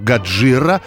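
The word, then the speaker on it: Годжира (ゴジラ): Доисторический ящер Годзилла, по-японски он произносится как Годжира, возродился после испытания водородной бомбы.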